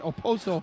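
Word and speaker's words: Okposo: Everybody says I pronounced the K too much, but Oposo.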